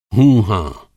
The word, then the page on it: हूँ हाँ: Hindi